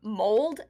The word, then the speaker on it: Mould: Mold? M-O-U-L-D? – That's how I pronounced it, too.